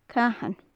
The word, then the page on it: cathan: gaelic